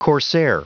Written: corsair

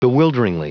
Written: bewilderingly

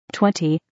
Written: 20

